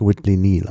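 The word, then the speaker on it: Whitley Neill: Nochmal zur, ich habe jetzt gerade nachrecherchiert, also es wird wirklich Whitley Neal ausgesprochen. – Okay, Whitley Neal, okay. – Ja. Das ist das Doppel-L bei Neal ist nur eine Schreibvariante, aber sonst der Vorname Neal, der ja keltischen Ursprungs ist. Und der wird Nil ausgesprochen, da gibt es gar kein Vertun.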